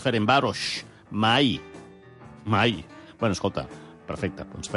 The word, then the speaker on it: Ferencváros: De fet, ahir a la ràdio, ahir a la tarda vaig escoltar nombroses ocasions el rival del Barça, Ferencváros. A més, amb una SH final molt pronunciada. Juga el Ferencváros. Com si fos una pista d'esquí.